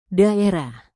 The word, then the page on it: daerah: indonesian